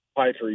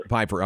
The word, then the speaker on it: Piper: Piper, yes, sir. – Piper, okay, gotcha.